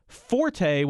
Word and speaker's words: forte: So forte, when we're talking about music, and I'm going to put the stress on the first syllable just because that's how we always said it when I was a musician, that's Italian. But when we're talking about someone's strength, it's properly said fort because the word is French.